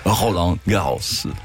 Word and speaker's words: Roland Garros: Roland Garros.